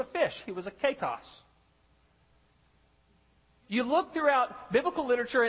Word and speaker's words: Ketos: It's, and I want to make sure I pronounce it right. Ketos. K-E-T-O-S is the transliteration.